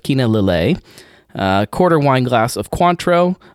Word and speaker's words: Lillet: I believe it's Lillet since it's French.